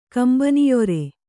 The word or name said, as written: ಕಂಬನಿಯೊರೆ